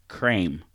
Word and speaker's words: Creme: On Tuesday, Cadbury, UK, erroneously told his Twitter followers in a now-deleted tweet that the cream in the candy's name is actually pronounced crème. – Crème. – So instead of cream, like C-R-E-M-E, it's actually C-R-E-M.